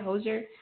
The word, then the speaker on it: Hozier: Hozier? – Yes.